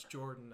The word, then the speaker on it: Jordan: And now that I have Jorbin as my name, everyone's like, is your name, your name's Jorbin? And I'm like, no, it's Jordan.